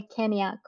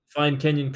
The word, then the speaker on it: Kenyon: She says it's the Kenya College, like the country. I'm like, no. – How did you find Kenyon College?